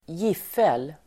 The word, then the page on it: giffel: [(²)j'if:el el. (²)g'if:el .]